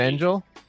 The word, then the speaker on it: Fengel: It's Fengel. It's okay. It's a soft G. – Fengel? – Yeah. – Sorry, Jordan Fengel.